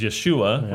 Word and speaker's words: Yeshua: And not only that, but the name of Joshua. – Yeshua, Is Yeshua, right? In the New Testament, how it would be pronounced is Jesus.